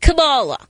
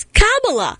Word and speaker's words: Kamala: not Kamala.